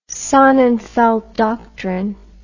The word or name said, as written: Sonnenfeldt Doctrine